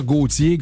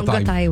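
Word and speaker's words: Gotye: Il y en a qui vont dire Gauthier, Gotay. – Non, Gotay, ouais.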